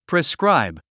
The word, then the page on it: prescribe: priskráib; プリスクライブ